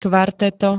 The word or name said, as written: kvarteto